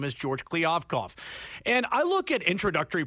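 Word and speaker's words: Kliavkoff: And, you know, you mentioned George Kliavkov, as now we know. It's a three-syllable name, so that's how I'll always remember it. – Did I get that right? – You did. Okay.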